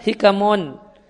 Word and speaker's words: hikamun: Linasakh hikamun. Itu bacanya hikamun. Ha kaf mim bisa dibaca hukmun, bisa dibaca hikamun.